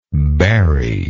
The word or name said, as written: barye